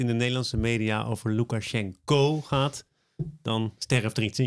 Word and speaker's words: Lukashenko: Hij heet Lukashenko. En je schrijft zijn naam met een O. Ja, in het Cyrillisch natuurlijk. Maar je spreekt onbeklemtoonde O's uit als een A. Dus deze man die spreekt zelf Russisch. En hij noemt zichzelf dus ook Lukashenko.